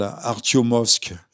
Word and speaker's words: Artyomovsk: qui prenaient Bakhmut Artimiosk, je prononce mal, Artyomovsk, euh, selon le nom russe, euh, vous savez, euh, Kharkiv, c'est Kharkov, etc.